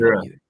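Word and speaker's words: Hiura: So, you know, maybe a McCutchen, Adames, and then I don't know, Brousseau, Hira. I can never say his name. – Hira. Yeah. Hira, you're right. It's Hira.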